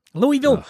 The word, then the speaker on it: Louisville: You know, I also appreciate that somebody from Philly is actually pronouncing Louisville right. – Louisville? Oh. Yeah. Louisville. We can say Louisville.